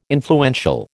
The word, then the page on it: influential: American English